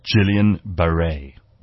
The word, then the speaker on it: Guillain-Barré: Apparently it's pronounced Guillain-Barré.